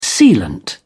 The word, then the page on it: sealant: British English